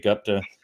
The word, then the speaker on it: Gupta: I'm probably going to butcher his name, Sanjay Gupta. – Sanjay Gupta. – Gupta, yes.